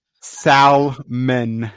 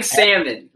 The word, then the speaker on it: salmon: Salmon. – He's saying salmon, like the fish. – Salmon. S-A-L makes sal. M-O-N makes mun. Salmon. Like salmonella. Salmon.